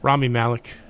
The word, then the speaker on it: Rami Malek: Rami Malek.